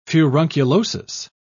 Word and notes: furunculosis: British English

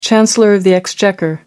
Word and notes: chancellor of the exchequer: American English

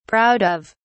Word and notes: proud of: British English

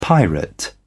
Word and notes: pirate: British English

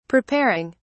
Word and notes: preparing: British English

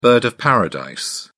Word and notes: bird of paradise: British English

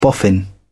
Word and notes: boffin: British English

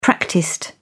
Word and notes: practised: British English

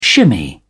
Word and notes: shimmy: British English

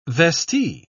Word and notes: vestee: British English

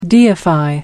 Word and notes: deify: American English

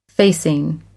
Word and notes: facing: American English

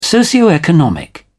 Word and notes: socioeconomic: British English

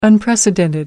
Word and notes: unprecedented: American English

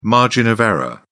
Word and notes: margin of error: British English

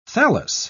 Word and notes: thallus: British English

